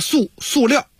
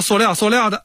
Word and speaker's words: 塑料: 反正这个考试的时候啊，尤其是中小学的同学们千万别错了，赶紧查字典去啊，标准读音就是塑料啊。但是咱们老北京的文化节目咱们从俗啊，咱就说塑料的啊，这个学生千万别错了，是塑料啊，但是咱们北京话就是念塑料，就这个意思啊。